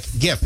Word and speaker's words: GIF: But if you want to be a stickler like my love over here, pronounce it as the word is. So graphic, that would make sense. Graphic GIF.